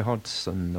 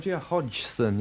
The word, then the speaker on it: Hodgson: Here's Roger Hodgson, or Roger Hodgson, yes, let's pronounce this correctly.